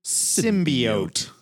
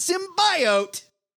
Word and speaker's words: symbiote: And it starts with how to pronounce the word symbiote. Not symbiote. – Come on, Jenny Slate.